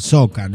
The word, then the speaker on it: soukous: O sea, ahí en esa región es el soca, ¿no? Lo que se escribe soukous, es una música que se traduciría como rumba congoleña, el soca.